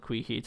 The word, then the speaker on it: Quihi: I think it's called Queehee, Texas. It's spelled Q-U-I-H-I.